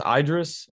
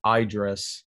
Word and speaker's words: Idris: So that's pronounced Idris. I was looking at Idris, like I, and you like put on a dress.